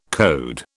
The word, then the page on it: code: British English